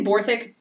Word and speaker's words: Borthwick: I'm saying Borthwick because usually that W is silent, just in case you're thinking about the spelling of it. So it's Borthwick.